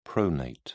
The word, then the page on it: pronate: British English